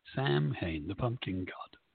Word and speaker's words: Samhain: We should stop saying Samhain because that's going to really tick someone off. It's pronounced Samhain.